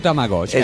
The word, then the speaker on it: Tamagotchi: E simulava la vita di un qualcosa, di un Tamagotchi, appunto. – Sì, un Tamagotchi, esatto.